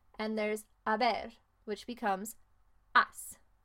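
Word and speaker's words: has: That's H-A-S. Remember, in Spanish, you don't really say the H. Maybe if you have a special dialect, you would, but the majority of the time, the H is silent.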